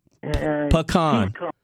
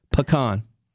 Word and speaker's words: pecan: Say it right. Pecan. Pecan. Say it with me.